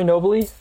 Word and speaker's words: Nobile: Yeah, Corey Nobley, and I'm the founder and CEO at Impact Snacks.